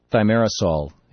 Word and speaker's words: thimerosal: And David, we've been talking about the research that suggests that there's not a link between vaccines and autism, or between thimerosal, if correct. – That's the correct pronunciation, and autism.